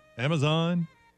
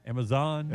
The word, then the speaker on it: Amazon: Amazon. – You say it weird. – Really? – Amazon. – Yeah.